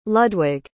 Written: ludwig

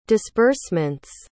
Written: disbursement's

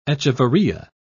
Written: echeveria

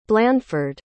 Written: blanford

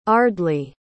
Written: ardley

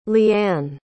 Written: leann

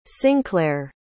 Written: synclair

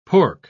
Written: pork

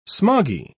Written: smoggy

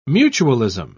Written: mutualism

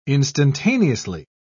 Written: instantaneously

